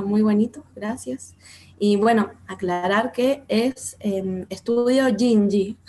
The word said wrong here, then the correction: Ginji: Ay, perdón. no, está bien. Como está escrito ahí medio raro, queda una confusión de cómo se pronuncia, pero es Jinji.